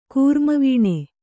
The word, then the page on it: ಕೂರ್ಮವೀಣೆ: kūrma vīṇe